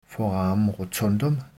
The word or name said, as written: foramen rotundum